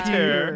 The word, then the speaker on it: Petyr: His name is spelled P-E-T-Y-R. – Yeah. – It's the old school Peter.